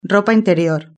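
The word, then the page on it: ropa interior: Spanish (Spain)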